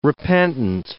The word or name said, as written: repentant